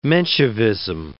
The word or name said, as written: Menshevism